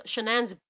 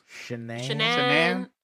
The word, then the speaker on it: Shanann: How do you pronounce the name? – Shanann. – Yeah, Shanann.